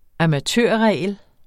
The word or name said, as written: amatørregel